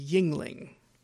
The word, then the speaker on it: Yuengling: And even though it looks like it should be pronounced Yuengling, it's actually Yuengling.